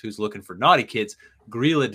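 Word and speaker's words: Grýla: The Grilla. – The Grilla.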